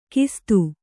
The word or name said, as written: ಖಸ್ಥ